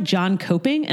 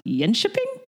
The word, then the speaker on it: Jönköping: it looks like John Coping, and apparently it's pronounced Jönköping.